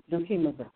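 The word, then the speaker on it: dokimazo: And pirazo is P-I-E-R-A-Z-O, and dukimazu, that's D-O-K-I-M-A-Z-O-O. Dukimazu. Well, I looked it up and also got the pronunciation. Dukimazu, is to test,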